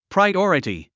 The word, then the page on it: priority: praiɔ́rəti; プライオラティ